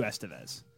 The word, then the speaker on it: Esteves: Thank you so much once again to Matthew Esteves. Just like Emilio Esteves. I asked him about that after. I'm like, did I say your last name right? And he's like, yes, you actually got it right.